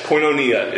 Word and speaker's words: koinonia: Koinonia? Yeah, very good. Or I would also accept koinonia if you want to pronounce it that way. I believe both are acceptable.